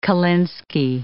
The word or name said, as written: kolinsky